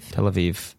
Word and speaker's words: Tel Aviv: Tel Aviv.